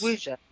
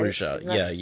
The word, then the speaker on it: Wuxia: It's W-U-X-I-A. I don't know how to pronounce it, but that's Wuxia. Right? – Wuxia, yeah. – Wuxia, okay.